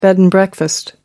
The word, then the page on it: bed and breakfast: American English